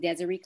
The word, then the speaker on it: Diseree: LinkedIn is just my name, Diseree Clay.